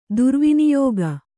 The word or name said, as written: ದುರ್ವಿನಿಯೋಗ